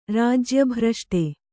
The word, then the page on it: ರಾಜ್ಯಭ್ರಷ್ಟ: Kannada